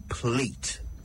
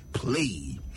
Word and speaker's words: plait: Do you say plait or plate? Well, it's spelled P-L-A-I-T, but it's pronounced plait in British English. The Americans, however, say plate. Some even say play.